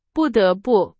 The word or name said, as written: 不得不